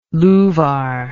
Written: louvar

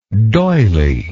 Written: doyley

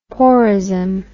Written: porism

